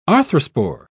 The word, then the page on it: arthrospore: British English